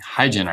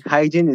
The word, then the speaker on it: Hygen: using Hygen, I believe is how you pronounce that.